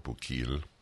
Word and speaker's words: Bukele: The name of the president of El Salvador, Nayib Bukele, if that is, I don't know how to pronounce it, B-U-K-E-L-E, Nayib Bukele, or Bukele. – Bukele. – It's pronounced Bukele? – According to the Colombians, yes.